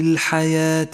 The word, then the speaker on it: الحياة: وذلة في الحياة الدنيا ولاحظ كيف لونت الواو في كلمة الحياة باللون الرمادي إشارة إلى أنها تكتب ولا تلفظ وهي من زيادات الرسم